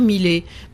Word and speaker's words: Millet: Donc en principe, il faut dire Millet. Mais Millet me gêne pas du tout.